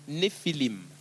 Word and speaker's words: Néphilim: Le mot géant ici, c'est un mot hébreu qu'on prononce Néphilim. Le singulier, le mot singulier, c'est Néphel. Au pluriel, c'est Néphilim.